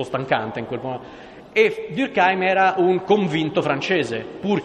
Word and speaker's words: Durkheim: Durkheim, il cognome è tedesco, la pronuncia è francese, tant'è vero che un mio collega dice che bisognerebbe pronunciarlo Durkheim, perché lui era dell'Alsazia-Lorena, e quindi non so se conoscete, vi ricordate la storia dell'Alsazia-Lorena.